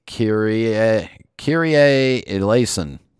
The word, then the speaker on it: Kyrie eleison: And from 2 weeks ago, it's kyrie eleison.